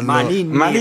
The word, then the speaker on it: Maninni: Maninni. Maninini. – Quanti sbaglieranno a dirlo. – Maninni, ragazzi, Maninni.